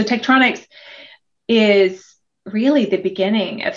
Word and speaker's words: Tektronix: we need more definition behind tech te- i can't say it Tektronix. – Did I uh-huh. say it right? it. nailed it.